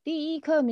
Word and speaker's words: 第一课: 第一课 means lesson one, correct?